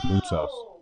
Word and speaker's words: Moutsos: The only thing he did was just mispronounce my name, Joe, but that's fine. It's Motsos.